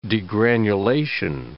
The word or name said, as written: degranulation